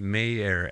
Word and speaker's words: Meier: It's pronounced and, Mayer, and it's also pronounced the Bronx.